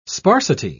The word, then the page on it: sparsity: British English